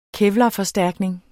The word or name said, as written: kevlarforstærkning